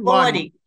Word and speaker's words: Lordy: Lordy.